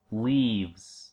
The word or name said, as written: leaves